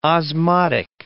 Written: osmotic

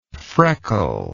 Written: freckled

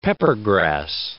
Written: peppergrass

